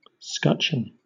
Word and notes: scutcheon: British English

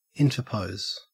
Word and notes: interpose: Australian English

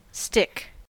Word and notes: stick: American English

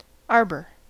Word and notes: arbor: American English

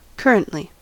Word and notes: currently: American English

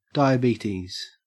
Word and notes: diabetes: Australian English

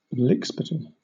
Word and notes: lickspittle: British English

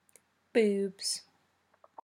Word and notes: boobs: British English

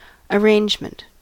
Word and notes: arrangement: American English

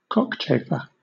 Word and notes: cockchafer: British English

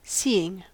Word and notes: seeing: American English

